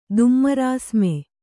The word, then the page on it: ದುಮ್ಮರಾಸ್ಮೆ: Kannada